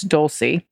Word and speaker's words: Dolce: Here's a pic of Dolce, pronounced like an S. Okay, so it's Dolce, not a K or a CH.